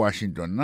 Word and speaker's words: Washington: Si nous mettons accent sur accent encore, on va voir que mot Washington, l'accent tombé sur le premier syllabe.